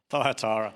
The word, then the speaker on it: Thyatira: So far we've looked at Ephesus, we've looked at Smyrna, we've looked at Pergamum, and we've looked at, I'm not sure how Tom pronounced this, Thyatira? Okay, pretty sure that's how the Greeks pronounced it. Thyatira. And so we've really been looking at Revelation and seeing more of who Jesus is.